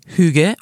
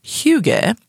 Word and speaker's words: hygge: Hygge, pronounced Hygge or Hygge. – I like Hygge.